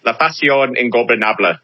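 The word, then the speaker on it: La Facción Ingobernable: La Faction Engobernable.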